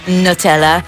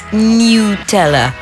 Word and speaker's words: Nutella: It's not Nutella. It's Nutella. Nutella.